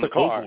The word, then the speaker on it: Stakar: Is it Stakar or Stacker or something Stakar.